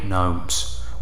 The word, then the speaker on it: Gnome: I hate gnomes with every fibre of my being. Gnome. Gnome. The very word is steeped in hideousness. The silent G stands for evil.